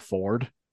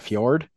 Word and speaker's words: Fjord: And tons of play on words to Fjord's name, because he pronounces it Ford in the game instead of Fjord.